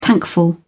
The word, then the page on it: tankful: British English